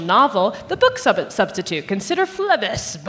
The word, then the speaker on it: Phlebas: Phlebas.